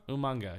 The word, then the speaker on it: Umaga: Was it Mongo or is it Umaga? – Umaga? – Umaga, yeah. – Okay. – I think I'm saying that right. – I think it's Umaga.